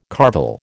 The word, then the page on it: carvel: American English